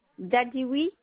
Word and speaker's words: Dadioui: Dadiwi Fatima. – Comment ça s'écrit, s'il vous plaît? – Alors, D-A-D-I-O-U-I.